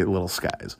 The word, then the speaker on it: Lil Skies: Skies wouldn't surprise me, but it's spelled S-K-I-E-S. So I'm going to say Lil Skies.